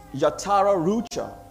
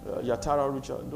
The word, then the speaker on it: Yatara Rucha: The spirit of excellence is in Aramaic is Yatara Rucha. Yatara Rucha.